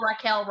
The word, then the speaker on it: Raquel: You say Raquel or Raquel? – Raquel, Rock.